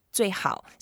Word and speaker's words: 最好: And then, of course, in our one of our episodes, we learn how to say the best, 最好.